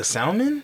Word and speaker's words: salmon: Why you pronounce the L in that shit? – Salmon.